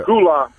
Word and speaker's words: Gullah: Gullah or gula is the way it's pronounced.